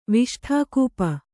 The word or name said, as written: ವಿಷ್ಠಾಕೂಪ